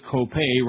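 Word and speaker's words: Kopay: I mean, that Dave Copay rhymes with gay.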